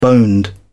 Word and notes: boned: British English

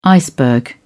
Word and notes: iceberg: British English

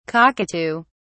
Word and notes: cockatoo(s): British English

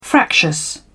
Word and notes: fractious: British English

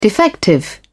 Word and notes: defective: British English

